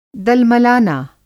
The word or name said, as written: दलमलना